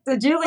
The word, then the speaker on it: Diguilio: Diguilio.